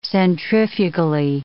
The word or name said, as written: centrifugally